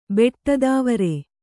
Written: ಬೆಟ್ಟತಾವರೆ

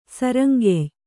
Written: ಸರಂಗೆಯ್

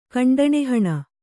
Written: ಖಂಡಣಿಹಣ

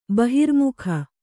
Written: ಬಹಿರ್ಮುಖ

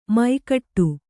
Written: ಮೈಗಟ್ಟು

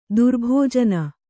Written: ದುರ್ಭೋಜನ

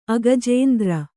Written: ಅಗಜೇಂದ್ರ

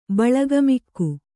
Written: ಬಳಗಮಿಕ್ಕು

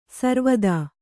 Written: ಸಾರವತಿ

